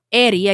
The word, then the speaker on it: Heriberto: Eddie, or Eri, again, the H is silent, and when the two R's, I'm not going to give you a Spanish lesson, Eri, a.k.a.